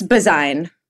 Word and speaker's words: Bazuin: let's get Nicole's last name pronunciation. It's Bazouin? – It's Bazouin.